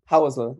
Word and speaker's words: Housel: Housel. It's Housel. – It's a German pronunciation.